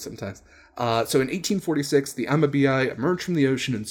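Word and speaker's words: Amabie: And it is the mythical Amabii, as I believe how it's pronounced. It's Amabii.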